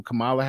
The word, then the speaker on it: Kamala: You better say it right. – Carmela Harris. – No. – That's not her name. – I can never pronounce her name. – Kamala.